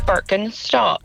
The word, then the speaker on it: Birkenstock: Birkenstock. – You see? It is good. What a lovely pronunciation.